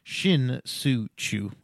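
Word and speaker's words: Shin-Soo Choo: Sin-Soo Chu, Shin-Soo Chu, I say it right because he won me a title,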